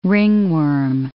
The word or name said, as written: ringworm